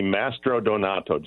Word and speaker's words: Mastrodonato: It's actually Mastronodonto, just like it's spelled.